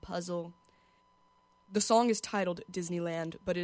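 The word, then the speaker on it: Dizz Knee Land: It's spelled Diz, like dizzy, and then knee, you know, like that thing for your leg, and then land.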